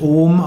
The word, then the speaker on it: Om: Und eigentlich wird Aum tatsächlich Om ausgesprochen, auch wenn im Westen oft die Vorstellung ist, dass es wie Aum ausgesprochen wird. Zwar wird gesagt, dass Om aus drei Silben besteht, A, U und M, und dem Nachklang, aber A und U verschmelzen hier zu Om.